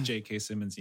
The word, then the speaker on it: J.K. Simmons: Just J.K. Simmons yelling at me. Did I say that right? Is it J.K. Simmons or is it Jacob Small? – Yeah, no, you got it there.